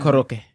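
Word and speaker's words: Koroke (コロッケ): But since it's from Japanese, or it's from Japan. – Koroke. – Koroke. Because that's how they say it.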